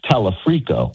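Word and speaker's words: Talarico: If you and I sit here on November the 4th and 5th and say, okay, we got Dr. Abdul, we have Tallarico, we have Angie Nixon, do you and I not perceive reality? Is that possible? – I think you're mispronouncing his name, Bill. It's Talafrico.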